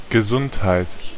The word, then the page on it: health: /hɛlθ/